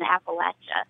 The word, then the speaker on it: Appalachia: I've never heard anyone from our area, from Appalachia, call it anything other than Appalachia. When I hear someone say Appalachia or Appalachia or however they pronounce it, to me, that signifies that person is not from the Appalachian region.